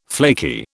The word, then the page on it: flaky: British English